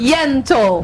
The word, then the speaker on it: Yentl: E ficou muito bom, Ó, a querida Alessandra Maestrini, ela tá em peça, que é a Ente. Out in Concert. – Yentl. – Eu vou fazer com pronúncia Yentl.